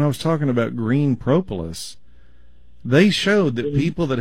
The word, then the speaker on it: propolis: P-R-O-P-O-L-I-S. Propolis. – Okay. – It's almost like the word Polish with a pro on the front of it.